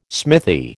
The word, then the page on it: smithy: American English